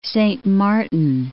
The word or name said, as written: Saint Martin